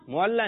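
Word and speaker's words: المعلیٰ: معلہ نہیں ہے, درست تلفظ کیجیے. – محلہ. – محلہ نہیں, آپ کچھ کا کچھ کر دیں گے اور کر رہے ہیں, صحیح ہے یہ نہیں ہے, محلہ نہیں ہے. عین لام.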